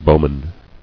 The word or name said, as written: Bowmen